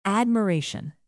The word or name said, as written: admiration